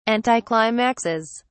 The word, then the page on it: anticlimaxes: British English